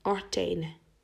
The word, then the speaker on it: Artane: I remember the medicine names was Artane, spelled like A-R-T-A-N-E, Artane. In French, it's Artane.